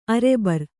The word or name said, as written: ಅರೆಬರ್